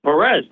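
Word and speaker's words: Pérez: Am I saying Salvador Perez's name wrong? And they go, no, it's Perez.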